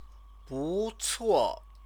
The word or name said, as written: bucuo